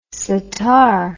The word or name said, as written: sattar